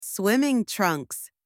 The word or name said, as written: swimming trunks